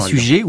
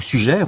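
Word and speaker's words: Suger: Oui, c'est sujet ou sujet, on peut prononcer des deux manières d'ailleurs, mais ce grand abbé est un personnage visionnaire.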